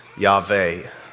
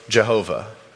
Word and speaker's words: Yahweh: In Hebrew, the sound of that sentence is Yahweh. I am. God's name is Yahweh.